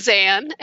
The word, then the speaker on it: Zan: And my name is spelled Z-A-N, so it rhymes with fan, but it starts with Z as in zebra. So it's Zan, and my last name is Bowden, and that's spelled B-O-W-D-E-N.